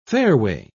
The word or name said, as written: fairway